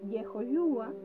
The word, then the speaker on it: Yehoshua: Por ejemplo, Yehoshua comienza con una Yod y una Hei al principio.